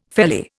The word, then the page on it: felly: Indian